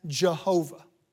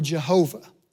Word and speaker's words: Jehovah: YHWH, pronounced Yahweh, or JHVH, pronounced in English as Jehovah. Yahweh or Jehovah.